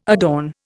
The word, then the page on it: adorn: Indian